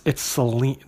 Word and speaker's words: Selene: And then when I looked it up online, it's Selene, they say it's Selene, with a long E, Selene.